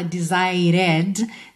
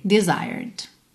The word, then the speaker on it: desired: Lembra que o E não é pronunciado, então você não fala desired, desire, né? Bota o R enrolado da porta com a perna esquerda e já fecha com D, desired.